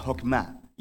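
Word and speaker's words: Hokmah (חכמה): En hebreo se pronuncia Hokmah y usualmente se traduce al español como sabiduría.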